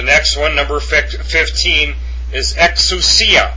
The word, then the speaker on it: exousia: Exousia, exousia, and that is the Koine Greek form for authority.